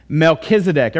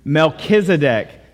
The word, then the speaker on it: Melchizedek: Melchizedek. Everybody say that with me. Melchizedek. Don't be scared of it. One, two, three. Melchizedek.